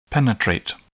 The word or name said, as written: penetrate